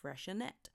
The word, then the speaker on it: Freixenet: and it was spelt F-R-E-I-X-E-N-E-T, and on paper looked like a little bit of a handful to pronounce. Knowing that many people wouldn't know how to pronounce it, and knowing that no one likes to look a fool at the bar, next to it they actually spelled it out phonetically, how to pronounce it, how to order it. And it was clear so that people felt comfortable enough to go and buy it. Apparently you pronounce it Freshenet.